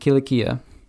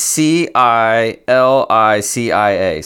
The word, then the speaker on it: Cilicia: With them they sent the following letter The apostles and elders, your brothers, to the Gentile believers in Antioch, Syria, and Cilicia Uh, all right, I'm going to stop right there.